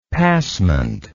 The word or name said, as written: passement